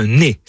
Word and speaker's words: ner: Il arrive aussi, notamment à la montagne, qu'au lieu de dire nègre ou nègre, on utilise le mot ner, N-E-R, prononcé soit effectivement ner ou même né.